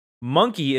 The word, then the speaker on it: Mynci: Mynci is a spelling of the word monkey using spe- Welsh spelling rules. Rather than a direct Welsh translation, it is pronounced like monkey.